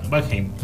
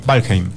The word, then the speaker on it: Valheim: No, bueno, eso es lo que tiene Se pronuncia Valheim, ¿cómo es? Valheim. Valheim.